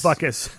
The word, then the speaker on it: Butkus: Butkus. And you pronounce that properly, by the way. – Butkus.